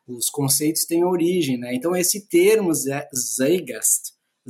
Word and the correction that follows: Zeitgeist: Zeitgeist, né? É um termo de pronúncia alemã.